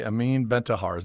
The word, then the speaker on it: Amine Bentahar: So I am very lucky today to be joined by Amin Bentahar.